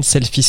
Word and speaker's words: Selphy: Selfie Square. Je crois que c'est ça. Enfin, c'est une Canon Selfie, pour sûr. – Alors, c'est, selfie s'écrit S-E-L-P-H-Y.